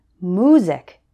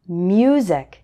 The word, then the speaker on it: music: Some English learners pronounce this word like music instead of music. Can you hear the difference?